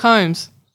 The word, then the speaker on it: Holmes: How do you pronounce the L? No one pronounces the L in Sherlock Holmes.